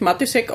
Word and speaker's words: Matysek: Ich spreche mich Matisek aus, aber eigentlich gibt es da so einen Hardcheck, also wo man das Matisek auch aussprechen kann, wie man will.